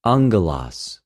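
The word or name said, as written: ἀγγελος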